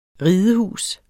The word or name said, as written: ridehus